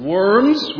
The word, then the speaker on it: Worms: and the word worms, which, by the way, is correctly pronounced Worms, is a city in Germany.